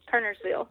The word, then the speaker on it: Kernersville: Yes, Kernersville.